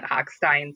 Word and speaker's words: Hochstein: It's actually hard for me because I'm a German major by trade, and so I want to pronounce it the real way, and his whole family pronounces it Hochstein, so that's what I go with.